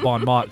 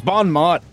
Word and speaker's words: Bon Mot: It's Bon Mot. That's how it's said in this universe.